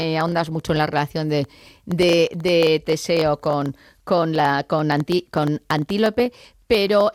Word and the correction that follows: Antíope: me gustaría no, lo he dicho mal el nombre, es Antíope.